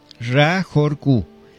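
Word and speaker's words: Ra-Hoor-Khu: Se escribe Ra-H-O-O-R. R, y se lee Hor, guión K-H-U, Ra Horku.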